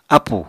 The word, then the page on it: ápù: Apatani